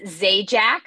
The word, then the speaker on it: Zajac: It's Zajac. I always say it's like Pat Sajak, but with a Z, not an S.